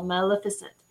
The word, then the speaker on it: Maleficent: Maleficent. You would say magnificent, but it's not a G, it's an L, Maleficent.